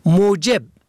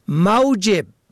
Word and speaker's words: موجب: موجب میم واو جیم بی به زم اول سبب، موجب به فتح اول معاش، این دو کلمه نیز کمتر مراعات می شود، در حالی که معنی خیلی متفاوت دارند.